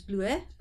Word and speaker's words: Bleuet: So if I were to write a book, a blurb on the back of the book Bleuet, which I pronounce as Bleuet, but whatever.